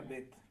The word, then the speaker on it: Bet: Esta letra se pronuncia Bet.